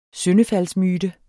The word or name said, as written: syndefaldsmyte